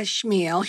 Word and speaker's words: Chmiel: And my name is Rita Chmiel here in the United States, but it's spelled with the C-H-M. So it's actually pronounced in Poland, in metal. The C-H is silent.